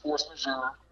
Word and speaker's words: force majeure: Yeah, I mean, I think that you know, honestly, it's pronounced force majeure,